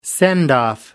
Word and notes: send-off: American English

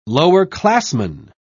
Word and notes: lowerclassman: British English

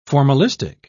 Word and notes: formalistic: British English

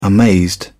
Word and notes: amazed: British English

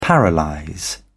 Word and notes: paralyse: British English

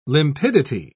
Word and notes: limpidity: British English

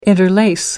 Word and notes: interlace: American English

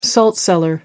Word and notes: salt cellar: American English

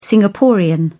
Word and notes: singaporean: British English

